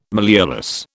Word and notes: malleolus: British English

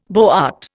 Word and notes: boart: Indian

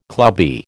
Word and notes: clubby: American English

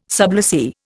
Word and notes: sublessee: Indian

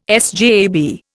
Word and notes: SJAB: Indian

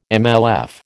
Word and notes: MLF: American English

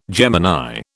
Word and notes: Gemini: British English

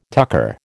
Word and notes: tucker: American English

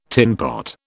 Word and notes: tinpot: British English

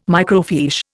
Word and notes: microfiche: Indian

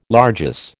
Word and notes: largesse: American English

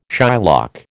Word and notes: Shylock: American English

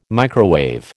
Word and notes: microwave: American English

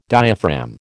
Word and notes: diaphragm: American English

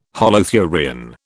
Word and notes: holothurian: British English

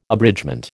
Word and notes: abridgement: American English

